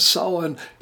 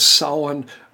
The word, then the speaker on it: Samhain: I'm saying it as Samhain, which is allegedly pretty close to the Gaelic. Just don't be a dork and say Sam Hain like it's spelled.